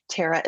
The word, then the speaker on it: Terra: Terra is spelled T-E-R-R-A.